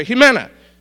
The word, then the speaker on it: Jimena: Now, I want you to say it with me, okay? First syllable, he, say it. Okay, Mena. All right, now put it together. Jimena. All right, that's good.